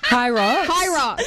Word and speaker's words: Hyrox: You said something about Hydrox. How do you say it? – Hyrox. Hybrid rock star.